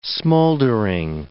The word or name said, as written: smouldering